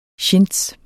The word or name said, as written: chintz